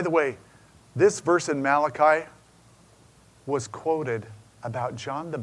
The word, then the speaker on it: Malachi: By the way, my messenger in Hebrew is pronounced Malachi.